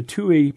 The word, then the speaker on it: tui: it reminds me of the song of the Tui, pronounced like the number two followed by the letter E.